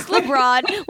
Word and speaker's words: LeBron: know if I'm pronouncing it correctly. – It's LeBron. When you said LeBron, I was like, should I correct her? – I'm quiet for a second.